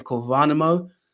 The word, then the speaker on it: Värnamo: I think the pronunciation was quite good, actually. The Värnamo part of it, it's not easy for you guys with no in the language.